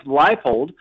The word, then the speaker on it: Leipold: First things first, though, I believe it's Lance Leipold. So let's make sure we're saying that right. But Lance Leipold.